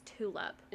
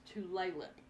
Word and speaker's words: Tulalip: Okay, see, when I was reading it, I thought it was tulip. – It looked like Tulalip. – Tulalip. – And but so, apparently it is pronounced Tulalip.